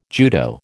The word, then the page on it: judo: American English